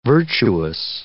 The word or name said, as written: virtuous